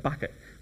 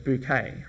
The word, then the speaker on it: Bucket: The last name is spelled Bucket, but they pronounce it Bouquet, right?